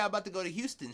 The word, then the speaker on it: Houston: But if you're in New York, there's a Houston Street, but it's not Houston, it's Houston Street.